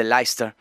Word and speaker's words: Leicester: The Specials va a venir seguido de Kasabian, el grupo que abre justamente desde Leicester. Leicester, como se escribe Leicester, como se pronuncia, bastante curioso este dato de Inglaterra.